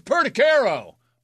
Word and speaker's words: Perticaro: He came and he goes, "It's Perticaro." Like, oh, sorry.